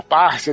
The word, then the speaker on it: Farsi: Inclusive, a língua deles não é o árabe, é o persa ou farsi, né? Que eles falam. Ou parse, dependendo de como é que você vai pronunciar. Né.